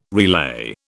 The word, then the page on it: relay: British English